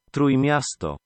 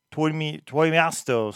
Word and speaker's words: Trójmiasto: So maybe that's a specific area in Poland that I'm not aware of, but Trójmiasto. – Trójmiasto. So better pronunciation there of the name of that city or area in Poland.